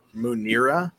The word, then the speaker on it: Munirah: What, Muniro? – Munira. – How do you spell it? – M-U-N-I-R-A-H.